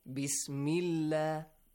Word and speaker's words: bismillah: But whenever you have a kasra sound, e, you gotta pronounce it e, li, li, li, lightly. Dilla, bismillah, ahdallah, bismillah, two different ones.